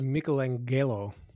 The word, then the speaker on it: Michelangelo: I say Michelangelo. That's how I pronounce it.